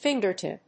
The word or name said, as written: fingertip